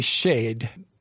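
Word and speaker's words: shade (שד): The Hebrew word will be shade. Phonetically, S-H-E-D.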